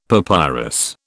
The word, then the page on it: papyrus: British English